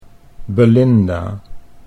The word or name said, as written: Belinda